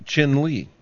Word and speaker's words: Chinle: There's a red star on this map, and just to the southwest of that, there is a designation for a town, and it's pronounced Chinle.